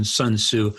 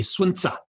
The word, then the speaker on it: Sun Tzu: Now, that's the American pronunciation, Sun Tzu. I've had many Chinese students try to teach me to say Sun Tzu, and I can't do it. So I just call him Sun Tzu.